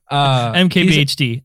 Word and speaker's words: MKBHD: MKBHD? – Yeah, you put emphasis on the H. – Anyway. – MKBHD. MKBHD.